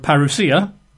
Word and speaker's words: parousia: The Greek word for coming is the word parousia. You'd spell that in English, P-A-R-O-U-S-I-A, P-A-R-O-U-S-I-A, parousia, which means arrival, you know, or coming as it's translated very often, in the sort of the papyri of the day.